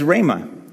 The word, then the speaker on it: rhema: Yep, some of you, how many of you heard of rhema? Rhema, you're supposed to actually say it like you have something stuck in your throat.